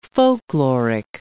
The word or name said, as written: folkloric